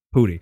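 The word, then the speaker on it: Pudi: Danny, is it Putty? Pootie? How do you say his name? – Pootie.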